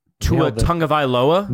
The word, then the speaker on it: Tua Tagovailoa: Why is Tua Tagovailoa not a Tua Tagovailoa? – Nail that pronunciation.